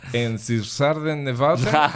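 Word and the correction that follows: Einstürzende Neubauten: Einstürzen de Neubauten.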